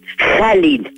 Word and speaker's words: Khalil: Comment vous le prononcez exactement? C'est Khalil Gibran? – Khalil. – Khalil. – Khalil. – D'accord. – Le S, c'est en arabe.